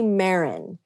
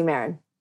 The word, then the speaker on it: Marin: Josie Marin. And it rhymes with Karen, which has gotten kind of a funny stigma in the last few years. But Josie Marin, Josie Moran.